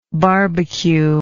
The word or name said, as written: barbque